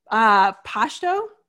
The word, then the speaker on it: Pashto: She is fluent in 5 languages, including German, Persian, Farsi, Pashto, did I say that right? – Yes.